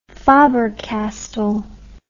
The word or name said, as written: faber castell